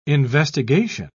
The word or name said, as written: investigation